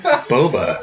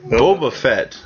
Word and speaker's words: Boba Fett: So how do you say it again? – Boba. – Boba. Okay. Boba Fett.